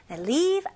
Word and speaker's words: leave: Leave out. 생략하다, 무시하다 라는 뜻들을 가지고 있습니다. 네, 똑같이 leave. 이거는 E 좀 길게 해주셔야 돼요.